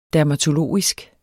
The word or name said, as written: dermatologisk